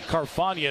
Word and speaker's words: Carfagna: Actually, Carfagna is a sophomore. C-A-R-F-A-G-N-A. You pronounce his name like lasagna. Carfagna.